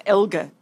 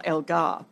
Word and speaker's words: Elgar: I mean, Dean Elgar's only lost, I have to say it the right way because he actually corrected me. Just so you know, Dean Elgar, it's Elgar, not Elgar, by the way.